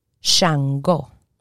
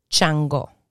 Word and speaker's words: Shango: Shango. Shango. And in Spanish, chango. So the sh turns into a ch.